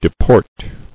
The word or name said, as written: Deporting